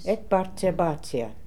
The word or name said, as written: Ekbarzerbazia